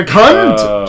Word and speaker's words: cunt: You're putting too much emphasis because, on the U, you know, it's more on the N. – Cunt. Yeah, you're putting the emphasis on the wrong part. – No, you're saying... You're saying as if it's spelled C-O-O-N-T. You're going coont. You still have to say unt. You just have to emphasize unt.